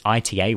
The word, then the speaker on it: ITA: Alitalia has now been replaced by a new airline called ITA or ITA. I'm not sure how you actually pronounce that. It's ITA, which stands for Italia Trasporto Aereo.